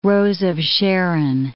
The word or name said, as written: rose of Sharon